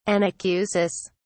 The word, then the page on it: anacusis: British English